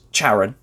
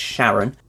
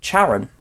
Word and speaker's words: Charon: It has five moons around it, the largest being Charon, or I think it's pronounced Charon, actually. I think everyone just sees the CH and goes, Charon, but I believe it's Charon.